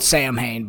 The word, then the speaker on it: Samhain: So, Halloween's likely oldest roots are in the Celtic festival of Samhain. It's spelled Samhain, but it's pronounced Samhain. Samhain